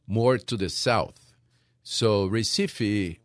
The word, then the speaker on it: Recife: And that just for folks, so it's spelled R-E-C-I-F-E. Looks like recipe with an F, but it's pronounced Recife.